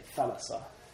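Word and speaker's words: thalassa (θάλασσα): Actually, thalassa. Pronounced with the accent.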